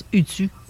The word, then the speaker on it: Utu: Euh, Utu, je ne sais pas si c'est comme ça qu'on le prononce, Utu. Utu. Utu Le U se prononce OU.